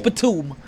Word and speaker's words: Batum: Yeah, that's not Batum. That's Batum. He's done. – Batum. I said that wrong.